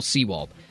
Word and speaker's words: Sewald: Sewald. Am I pronouncing that correctly? – You are, yeah.